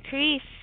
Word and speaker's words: Creese: Nina Creese.